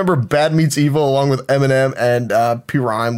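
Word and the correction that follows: PRhyme: with DJ Premier. – Yeah. – Prime. Prime.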